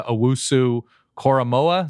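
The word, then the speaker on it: Owusu-Koramoah: And for what Notre Dame was doing up front, forcing turnovers, what it was, Jeremiah Owusu Koromoa, is that how you pronounce it? – You got it.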